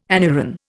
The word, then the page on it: anuran: Indian